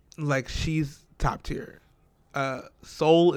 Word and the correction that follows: Saul: is annoying. Saul, first of all, let me say his name right.